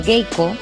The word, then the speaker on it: geiko: En la región de Kinri, los términos geiko, que es como se pronuncia, como se les llama allí, y para referirse al aprendiz sería maiko.